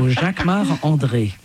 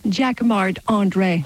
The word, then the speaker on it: Jacquemart-André: Jacquemart-André. You say it like a Frenchman. – I'm French. So Jacquemart-André. – And say it so we can understand it. – Jacquemart-André.